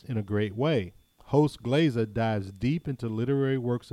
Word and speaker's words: Glaiza: So, another podcast I want to tell you about is called Classical Glaza, and I hope I'm pronouncing it right. I'm sure I am. Glaza is G-L-A-I-Z-A.